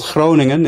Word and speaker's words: Groningen: Yes, life started out in a town called Groningen in the Netherlands for me.